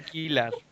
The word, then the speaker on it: Gellar: Michelle Gellar, Gellar, se escribe Gellar, se pronuncia Gellar.